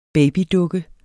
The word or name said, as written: babydukke